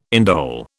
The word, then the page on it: indole: British English